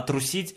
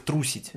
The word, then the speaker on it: трусить: А трусить это, это также пишется как трусить. Да. То есть, поменяв ударение, да.